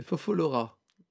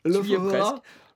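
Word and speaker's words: Lophophora: C'est Fofolora. – Presque. – Fallout Boy. C'est... non plus. Lofofora. Lofofora, yes.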